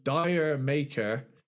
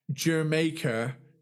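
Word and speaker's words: D'yer Mak'er: Now, it took me till recently diving into this to realize that the song I've been calling Dire Maker is pronounced Jermaker because it's a fucking reggae song.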